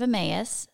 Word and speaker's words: Emmaus: Or how do you say it? – Emmaus.